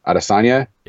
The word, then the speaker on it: Adesanya: Adesanya.